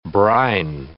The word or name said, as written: brine